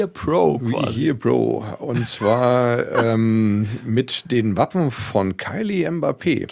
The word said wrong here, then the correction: Kylian: Kylian Mbappé, Mensch. – Also Kylian, du alter Fußballfan. – Einfach nur Englisch ausgesprochen.